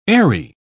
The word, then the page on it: aery: English; British English